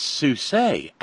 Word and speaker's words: Suisset: It looks in print, it looks like Souse, which makes perfect sense for it. It's Suisset, accent grave over the E.